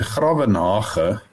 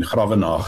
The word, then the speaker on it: Gravenhage: But where does the name come from, and how do you actually pronounce it properly? – It's a Dutch word, Gravenhage, and I'm not sure what hage means, but graven is a spade.